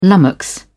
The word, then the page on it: lummox: British English